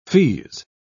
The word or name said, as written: feeze